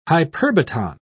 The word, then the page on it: hyperbaton: British English